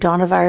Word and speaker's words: Dohnavur: And Dohnavur is spelled D-O-H-N-A-V-U-R, Dohnavur Fellowship, which is still in existence today.